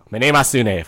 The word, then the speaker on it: Mnemosyne: Mnemosyne, folks. That's the official pronunciation. That's how it's said in Greek.